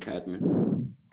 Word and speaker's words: Cadman: Donovan Cadman.